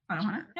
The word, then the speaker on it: Anohana: wanted to talk about Anohana, the flower we saw that day. – Mm-hmm. – Am I saying that right? – Uh, yeah, yeah, Anohana, yeah.